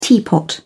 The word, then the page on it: teapot: British English